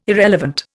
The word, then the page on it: irrelevant: Indian